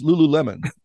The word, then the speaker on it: Lululemon: You've never said it correctly to me. It's Lululemon.